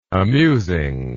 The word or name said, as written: amusingness